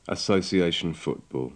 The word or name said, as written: association football